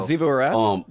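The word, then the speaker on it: Xivu Arath: I can't pronounce that damn name. You're going to help me every time. Who? Zivu, whatever the hell. – Zivu'arath? – Yeah, okay.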